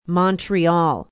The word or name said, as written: Montreal